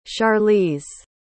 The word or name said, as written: charlize